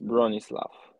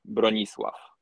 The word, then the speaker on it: Bronisław: And when I hear you say it, is there an F at the end? Bronisław? – Yes, Bronisław. – Okay, but the last letter is a W. – W, yeah. – But you pronounce it like an F. – Yeah, in Polish, it sounds like Bronisław.